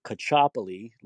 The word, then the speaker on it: Caccioppoli: My name is Mike Cacciopoli. Lance did not pronounce it correctly.